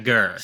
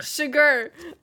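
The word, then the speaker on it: Chigurh: How do you pronounce his last name? – Sugar. – Sugar. Yes, not sugar.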